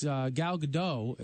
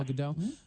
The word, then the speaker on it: Gal Gadot: And the story about the actress, Gal Gadot, if that's how, is that how you say her name? – Yes, very good, yeah. – Gal Gadot.